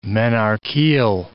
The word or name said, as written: menarcheal